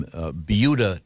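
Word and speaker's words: Buda: we have yet to get to sports, but we were discussing a very interesting news item from Beuda, Texas.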